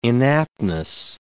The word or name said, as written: inaptness